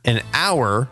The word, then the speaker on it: hour: So we say an honor, an hour, or an heir. Like an heir to the throne. For example, because the initial letter H in all three words is not actually pronounced.